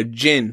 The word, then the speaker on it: djinn: Sometimes you pronounce the D. Sometimes you don't. Djinn or genie.